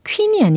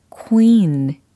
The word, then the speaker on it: queen: 퀸이 아니고 queen. 바로 이 발음입니다. Q U E E N.